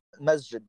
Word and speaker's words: masjid: Masjid. That's the name in Arabic, yes.